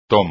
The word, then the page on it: toma: espagnol